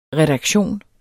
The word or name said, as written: redaktion